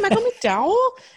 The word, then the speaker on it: Malcolm McDowell: That's yeah, I was like, is that Malcolm McDonald? McDowell? See, now I'm saying it wrong. Is that Malcolm McDowell?